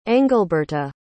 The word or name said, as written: engleberta